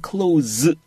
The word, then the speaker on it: close: 지금처럼 닫다 할 때는 close, 이렇게 z 발음을 해주세요.